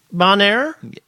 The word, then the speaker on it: Bonnier: So, you know, motorcyclist, part of the is it Bonnier? Is that how you pronounce the publishing group? I think Bonnier. – Is it? It's Bonnier.